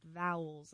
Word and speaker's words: AEIOU: That's how it's spelled, but it's technically pronounced vowels, I recently learned.